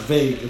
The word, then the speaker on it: vague: W-A-G-U-E, wagyu. So the teacher is obviously like, oh, listen, the word is vague. It's not wagyu. It's pronounced vague, okay? The U and the E are silent.